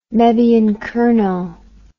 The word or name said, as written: mebyon kernow